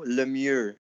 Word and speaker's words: Lemieux: Mario Lemieux. – Whoa. – Right? Not Lemieux, Lemieux.